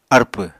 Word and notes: arpw: Apatani

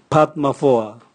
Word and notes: bwatmavoa: Raga

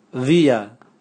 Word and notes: via: Raga

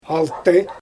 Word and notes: alty: Xyzyl

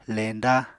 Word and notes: leñda: Apatani

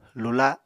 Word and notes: lula: Apatani

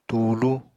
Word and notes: tuulu: apatani

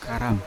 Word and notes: karam: Yawa

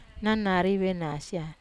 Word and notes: nanaria venasia: Wamesa

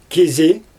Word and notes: kizi: Xyzyl

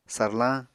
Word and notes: sarlañ: apatani

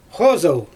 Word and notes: xozɯl: Xyzyl

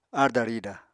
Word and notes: aarda-rida: Apatani

